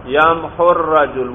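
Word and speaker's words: يمحو الرجل: تو واو ہونا چاہیے، لیکن وہ واو اجتماع ساکنین کی وجہ سے تلفظ سے گر جاتا ہے. یمحو یمحو واو ہے، آگے ذرا الرجل ملائیں ساتھ، یمحو الرجل، واو گر گیا یا نہیں گر گیا اجتماع؟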